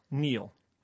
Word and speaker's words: kneel: K-N-E-E-L, Neil. – Raspberry, answer. – Silent right. letters. – Yes, silent letters. Oh, yeah. Raspberry is... – The silent S... – P. – Or a P. Sorry. – Silent P, right. Or answer. – P. W. – W yeah. and Neil. – Okay. – The K, which was silent until I spelled it earlier in the clue.